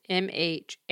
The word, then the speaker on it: Mhairi: Next week is Between Us by Mahari McFarlane. I don't know how you actually pronounce it. It's Mhairi. Mm-hmm.